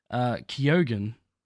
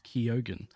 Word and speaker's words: Keoghan: This thread was dropped after Chloe Zhao saw how well Barry Keoghan is. I had to look that how to say his last name. Keoghan.